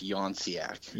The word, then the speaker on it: Younciak: Start out with, how do you say your last name? – So my last name is Younciak. – Younciak. – Gotcha. Yeah. Say it a couple times to yourself tonight, and you'll remember it.